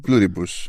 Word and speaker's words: Pluribus: Αυτό θα είναι Pluribus, αφού είναι, αυτό θα είναι λατινικά, ε.